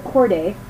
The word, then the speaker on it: Corday: Charlotte Cole. It's like the easiest thing to say, and I can't. It's fine. Charlotte Corday. Yeah, C-O-R-D-A-Y.